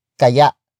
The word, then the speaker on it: kaya: Kaya. Kaya. Kaya is spelled as K A Y A. Note the glottal stop at the end.